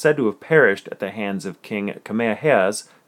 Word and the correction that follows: Kamehameha: military not Kamehamehas. It's so hard not to say it like that. But King King Kamehameha's military. Kamehameha's, yes, thank you.